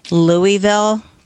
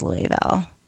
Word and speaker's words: Louisville: And then where you have Louisville, Kentucky, it's not pronounced Louisville or Louisville.